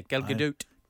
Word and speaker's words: Gadot: You sort of sometimes pronounce the T's, sometimes you don't. – It's a very fun. Well, I always just go, oh, these simpletons that are not pronouncing it Gal Gadot. And then I heard her say it as a soft T, and I'm like, yeah, Gal Gadot.